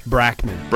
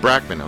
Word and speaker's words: Bachman: Today, Gilles personally manages legendary icons Randy Bachman? How do you say that? – Brackman. – Brackman, okay.